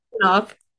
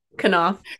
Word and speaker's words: Knauf: Knauf. Knauf. – Knauf. – Pronounce it like knife.